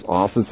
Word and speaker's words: Asa: One of those great passages of the Old Testament on this, a man named Asa, sometimes pronounced Asa, said, A-S-A, you pronounce it, all right? I pronounce it Asa, all right?